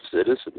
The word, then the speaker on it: situs: It's spelled S-I-T-U-S. Now, some people do situs. I do what I think is the American way. I just look at it exactly the way it's written, and it says citus to me.